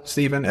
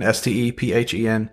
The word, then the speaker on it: Stephen: Stephen, like the Bible, Stephen, Stephen, and then Mceill is my last name.